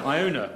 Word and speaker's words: Iona: After Iona, which rhymes with boner.